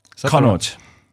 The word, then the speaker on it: Connacht: And then the away South African teams, the Lions, they played, I don't know how to say this, Connacht. – Connacht.